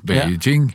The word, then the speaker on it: Beijing: Uh, de, I Peking så vil de Jeg sier Peking også, det er gammel vane. – Beijing. – Ja. Men det er så gammel Peking. Det var den gamle uttalen.